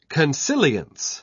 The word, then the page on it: consilience: British English